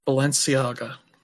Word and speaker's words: Balenciaga: How do you pronounce that? – Balenciaga. – Yeah, Balenciaga.